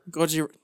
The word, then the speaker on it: Gojira: Gojira.